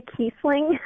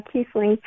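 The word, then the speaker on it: Kiessling: And it's Rebecca Kissling, by the way. – Oh, how did I say it? I'm sorry. Kissling. Okay. – Yeah, Kissling.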